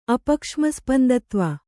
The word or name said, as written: ಅಪಕ್ಷ್ಮಸ್ಪಂದತ್ವ